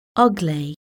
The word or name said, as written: ugly